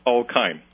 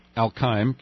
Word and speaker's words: Al-Qaim: Well, I mean, based on the administration reports, really the Sunni triangle, the border area that you visited near Qaim, is that how you say it? – Al-Qaim. – Al-Qaim, Q-A-I-M. – That's correct. – And a few other zones are where, you know, there is a relatively consistent violence and a high level of risk.